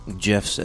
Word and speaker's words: GIF: I told you that the inventor of the GIF came out and said that it's pronounced GIF. – Mm-hmm. Okay. Yeah. That's fine. – So the pronunciation is GIF.